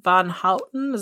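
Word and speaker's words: Van Houten: Well, Leslie um, Von Houten, is that how you say her name? Mm-hmm. She said in a later interview that she doesn't actually ever really remember Charles taking anything.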